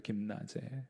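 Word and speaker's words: 김나제 (γυμνάζε): 연단한다 하는 표현은 우리 헬라어로 김나제라고 읽어요. 김나제.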